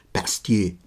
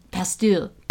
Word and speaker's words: Pasteur: I was going to say, I believe it's pronounced Pasteur. – Pasteur.